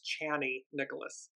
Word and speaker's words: Chani Nicholas: I apologize, I've been saying her name wrong. It's Chani Nicholas, incredible astrologer.